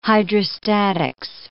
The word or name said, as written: hydrostatics